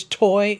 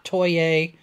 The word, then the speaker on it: Toye: And it is Toy. It's not Toye. Like a lot of people have been pronouncing it. I watched the deposition. I heard how he pronounces his name. So it's sure, Ron Toy.